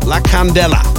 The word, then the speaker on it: La Candela: The new tune, La Candida, is that how it's pronounced? La Candela, La Candela.